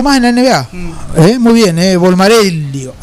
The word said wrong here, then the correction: Bolmaro: Hasta, ¿Lo dije bien, Bolmaro.